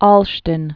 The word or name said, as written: Olsztyn